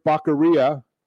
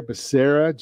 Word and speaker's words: Becerra: Yeah, apparently they didn't get the pronunciation or the phonetics into the teleprompter for Joe Biden because it's Javier Becerra, Joe.